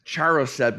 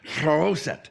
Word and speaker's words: charoset: After that, I've been pronouncing it Charoset, because that's the anglicized pronunciation. I was talking to a messianic friend the other day. He said, Patrick, it is Charoset. And he seemed very concerned that I correct that with you.